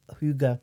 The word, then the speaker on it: hygge: Hygge. – Yeah. – Yeah. – So that's spelled, if anyone wants to Google it, it's spelled H-U-E-G-U-H.